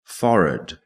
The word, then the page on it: forehead: British English